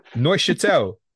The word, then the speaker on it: Neuchâtel: This is a Swiss team. Nuchtamel Jamax. – Neuchâtel? – Fuck me. Cheers, mate. Yeah. Neuchâtel.